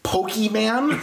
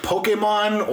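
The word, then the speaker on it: Pokémon: If you say the word... Pokemon, and not like Pokemon or yeah, like anything that's close to the way it's supposed to be pronounced.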